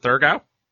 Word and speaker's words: Thurgau: Now, you ended up going to Switzerland and you played for, fix the pronunciation for me, is it Thurgau? – Yeah, yeah.